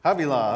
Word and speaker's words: Havilah: How do you say that? Havilah. All right, I got it right.